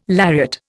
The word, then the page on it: lariat: Indian